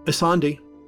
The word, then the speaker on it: Ysonde: It's spelled Y-S-O-N-D-E. – That's an unusual name. Is it Isonde? – Isonde.